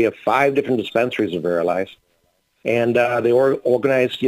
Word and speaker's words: Verilife: Verilife? Am I pronouncing that correctly? Sure.